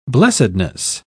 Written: blessedness

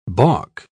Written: balk

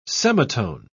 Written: semitone

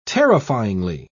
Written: terrifyingly

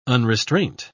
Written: unrestraint

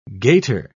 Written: gaiter

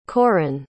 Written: corren

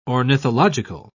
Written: ornithological

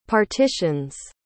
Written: partitions

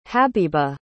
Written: habeeba

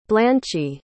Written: blanchie

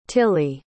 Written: tilley

